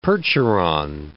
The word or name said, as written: Percheron